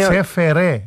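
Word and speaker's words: CFR: Τσεφερέ. Έτσι, τσεφερέ διαβάζεται το ΣΕΦΕΑΡ Cluj.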